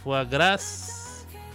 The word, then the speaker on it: foie gras: Este, foie gras. Bueno, la S no se pronuncia y el foie, eh, la E no se pronuncia en el foie gras foie es un producto alimenticio del hígado hipertrofiado de un pato o ganso que ha sido especialmente sobrealimentado.